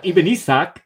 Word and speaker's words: Ibn Ishaq: Ibn Ishaq.